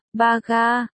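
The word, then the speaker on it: ba ga (バカ): Sắc mặt Tỉnh Thượng Xuyên đại biến, bát sát, ba ga, phát âm là, ba ga, đọc gần giống như, ba ca,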